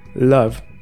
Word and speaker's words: Loeb: Pero bueno, imagínense ustedes que esta cosa, esta piedra causó tanto revuelo que un astrofísico llamado Abraham Love, no sé si lo estoy pronunciando bien, no es Love como amor, no, no sean tan románticos ahorita. Es L-O-E-B alta, ahí casi casi, pero no.